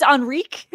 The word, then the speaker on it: Henrique: Did I completely butcher it? It's Henrique. – It's Adam Henrique.